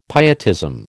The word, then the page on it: pietism: American English